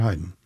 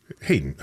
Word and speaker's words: Hayden: Do you say Hayden or Haydn? – Hayden. Haydn is the composer.